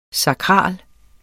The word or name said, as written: sakral